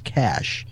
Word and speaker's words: cache: According to Oxford Dictionary, the word cache is pronounced cash,